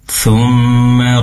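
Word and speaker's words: thumma (ثم): Ketika membaca thumma, pastikan mimnya pendek. Thumma rodadena, pendek ya, tidak dibaca lebih dari satu harokat.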